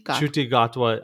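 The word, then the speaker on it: Ncuti Gatwa: How do you pronounce the person's name? – Shuti Gatwa.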